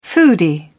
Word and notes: foodie: British English

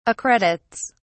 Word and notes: accredits: British English

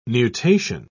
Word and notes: nutation: British English

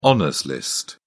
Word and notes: honours list: British English

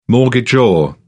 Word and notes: mortgagor: British English

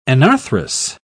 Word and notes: anarthrous: British English

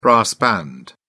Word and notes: brass band: British English

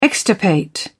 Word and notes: extirpate: British English

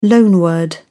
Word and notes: loanword: British English

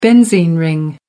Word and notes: benzene ring: American English

